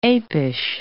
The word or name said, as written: apish